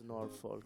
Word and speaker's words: Norfolk: Ti è piaciuto come ho detto Norfolk? – Madonna, mi fai sempre emozionare. – Ma tantissimo, per la tua pronuncia.